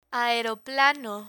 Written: aeroplano

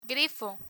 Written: grifo

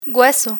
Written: hueso

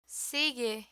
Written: sigue

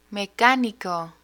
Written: mecanico

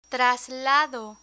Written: traslado

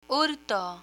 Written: hurto

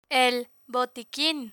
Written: el botiquin

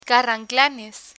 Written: carranclanes

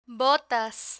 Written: botas

